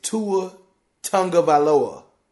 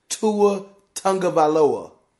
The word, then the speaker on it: Tua Tagovailoa: Hey, Adam Schefter, it is Tua Tagovailoa. Tua Tagovailoa. That's how you say it.